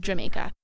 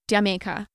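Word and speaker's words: D'yer Mak'er: I always just say Jamaica because someone once told me that the name of the song was meant to sound like someone saying Jamaica. So Jamaica.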